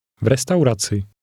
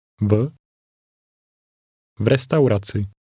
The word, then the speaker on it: v restauraci: V restauraci. Ci. Raci. Restauraci. Restauraci. V restauraci. – It's the v sound at the beginning, which means at.